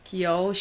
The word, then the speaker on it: Keough: But it stars Jenna Malone, and I don't know how to say this girl's name, Riley Keough. I don't know how, it's like, that's an O and a U and a G and an H. – Keough.